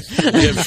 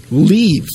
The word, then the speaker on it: Liev: Liev, though. – He pronounces it Liev now. – Oh, does he? – Yes. Liev. It used to be Liev Schreiber, but now it's Liev.